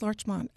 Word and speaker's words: Larchmont: Where is Larchmont? Am I pronouncing that right? – Yes, Larchmont.